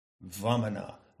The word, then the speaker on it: Vamana: So I take the pronunciation of Vamana, where the V and the W, the V is close to a W, you catch that, from a YouTube channel which appears to be dedicated to a genuine and, what shall I say, authentic pronunciation of Sanskrit words, Vamana, obviously a word I enjoy saying.